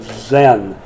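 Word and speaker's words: Zin: Zen. It looks like sin. It's pronounced like Zen.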